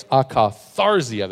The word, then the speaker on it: akatharsia: It's akatharizia. And I didn't pronounce that right. It's akatharsia. There we go. I can get that right.